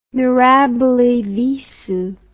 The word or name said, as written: mirabile visu